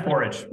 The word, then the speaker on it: Forij: Yeah, Forij.